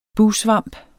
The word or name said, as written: bugsvamp